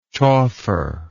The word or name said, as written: chauffer